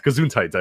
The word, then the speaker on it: Gesundheit: Gesundheit, I believe, is the correct way to say it.